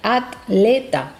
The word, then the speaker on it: atleta: Porque en unas zonas del español se prima más la división silábica atleta. Okay. Entonces se pronuncia atleta, como tres sílabas, ¿no? Y en otras zonas del español se pronuncia la combinación TL formando parte de la misma sílaba, atleta. Es una combinación que no es normal en las sílabas en español.